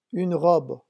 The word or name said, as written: une robe